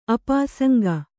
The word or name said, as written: ಅಪಾಸಂಗ